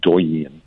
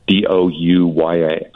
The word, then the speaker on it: Douyin: In China, there's one called, and I'm going to mispronounce this, I think it's pronounced Douyin. Douyin.